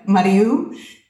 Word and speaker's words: Mariu: So we have Mariu, which is spelled M-A-R-I-U, and we have Luna.